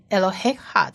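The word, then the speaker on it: Eloheha (אלהיך): 接下来是最后一个字，就是上帝 Eloheha 有四个字母要学，第一个字母是 Aleph 末音字母不发音，而它下面的这一个母音是非常短的一个 A 的音， Eloheha 的 A，这个 A 发音就好了。再来下一个字母是 Lamed 发勒的音，它左上方的那个一个黑点是长母音 O，所以是 low Eloheha 的 low。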